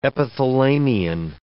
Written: epithalamion

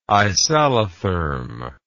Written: isallotherm

